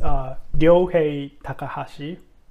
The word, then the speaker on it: Ryohei Takahashi: Yes, it's Ryoshei Takahashi, but for some reason, it's hard to pronounce Ryo, R-Y-O. – Yeah. – So people just call me Yohei.